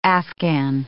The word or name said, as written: Afghan